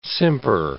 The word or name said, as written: simper